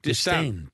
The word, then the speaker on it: Distin: Sylvain Distin. – Distin. – Distin. – Distin. – Distin. – Distin, yeah. – Distin. – Get his name right.